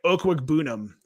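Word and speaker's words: Okwuegbunam: But he put the pronunciation in his Twitter profile, oh, his Twitter really? Bio. So oh. Yeah, it's like Okwugbenum.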